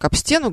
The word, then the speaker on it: об стену: Я очень часто слышу выражение как об стену горох. На самом деле, конечно, здесь на этот на предлог будет падать ударение.